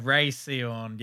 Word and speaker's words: Rhea Seehorn: Yeah, I think I didn't want to butcher her name because I think it's just pronounced Ray. – Yeah, Rhea Seehorn. Yeah, that seems right.